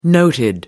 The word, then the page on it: noted: American English